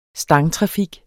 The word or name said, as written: stangtrafik